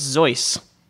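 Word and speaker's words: Seuss: that the way you just pronounce his name... is the way I would pronounce the name and the way pretty much everyone does. – Oh, what is it? – Apparently wrong. Apparently it's Zeuss.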